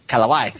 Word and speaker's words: Kalawai: Kalawai, sorry.